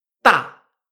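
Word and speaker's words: TA (大): Por ejemplo, el ejemplo que tenemos aquí es DA con cuarto tono. Esto se pronuncia TA, TA con cuarto tono, recordamos.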